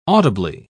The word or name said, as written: audibly